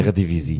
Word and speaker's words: Eredivisie: Die haben alle 16 Spiele in der Eredivise, ich hoffe, ich habe es richtig ausgesprochen, Eredivisie.